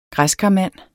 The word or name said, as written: græskarmand